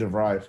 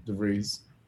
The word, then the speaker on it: DeVries: I kept saying DeVry for a long time because of the school, but DeVries. – That's nuts. – At least that's how they say his name in all of BBC News.